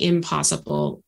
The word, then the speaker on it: I'm possible: impossible possible. And I think it's I'm possible. I don't know. Is that how you pronounce it? – It is the I'm possible journey.